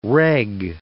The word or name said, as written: reg